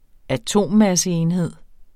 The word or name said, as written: atommasseenhed